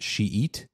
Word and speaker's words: Schiit: Shita. – Mm-hmm, mm-hmm. The one spelled S C H I I T. Right. That if it was actually German, it would be pronounced Shiit, not okay. Shita.